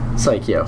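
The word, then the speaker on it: Psikyo: How do you pronounce the name of that company? – Psycho. – Psycho? Okay.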